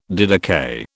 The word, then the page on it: didakai: British English